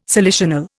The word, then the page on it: salicional: Indian